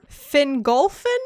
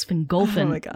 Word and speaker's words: Fingolfin: No, but you even got the emphasis right, because the emphasis goes on the second to last syllable. So it's Fingolfin, not Fingolfin. It's Fingolfin and Finarfin.